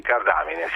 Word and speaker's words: cardamine: È vero, perché i cavoli e la loro famiglia, anche queste cardamine? – Okay. Sì, cardamine, sì. – Non ho sbagliato l'accento. Cardamine, ma quanto sono brava!